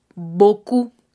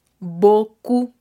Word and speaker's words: beaucoup: Decimos boku, boku. Se escribe b e a u c o u p.